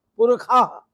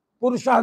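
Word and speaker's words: पुरुषः: पुरखा, पुरुषाह नहीं कहेंगे पुरखा कहते हैं, शाह का उच्चारण खा करते हैं।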